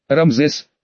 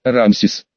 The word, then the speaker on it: Рамсес: Рамзес, правильно произносить, Рамсес.